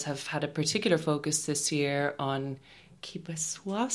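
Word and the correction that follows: Québécois: cinema. Am I saying that right? Kibeswa? – It's the imagination. – Quebecois cinema. – Quebecois. Okay, yeah. Perfect.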